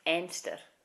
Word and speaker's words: Anstruther: There's a place in Fife I've been there, and they pronounce it Enster, but it's called Anstruther.